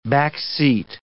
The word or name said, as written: backseat